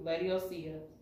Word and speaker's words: Laodicea: Laodicea.